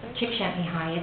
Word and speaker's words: Csikszentmihalyi: Csikszentmihalyi.